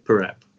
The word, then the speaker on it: Parap: Parap.